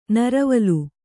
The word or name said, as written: ನೀರುವಲೆ